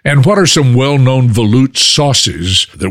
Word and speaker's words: velouté: Now let's move over to velouté sauces. Did I say that right? – Mm-hmm. You sure did.